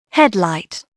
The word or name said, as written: heal